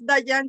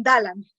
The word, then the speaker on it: Dallan: Mi nombre es Dayan Ruiz Prada, pero en Estados Unidos, mi nombre se escribe con doble L. Mi nombre es en Estados Unidos, si yo digo Dayan, me lo escriban Diane. Y si me lo leen, entonces me dicen Dalan.